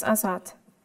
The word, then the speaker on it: Azad: It is Nargis, Nargis Azad.